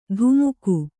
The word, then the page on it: ಧುಮುಕು: Kannada; dhumuku